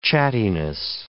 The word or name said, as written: chattiness